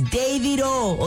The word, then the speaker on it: Davido: Shout out to Asa, man, Davido's manager. He be like, Sheila, what's all these? Davido, Davido, it's Davido.